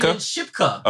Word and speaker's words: Kiernan Shipka: Kiernan Shipka.